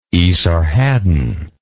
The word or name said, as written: Esarhaddon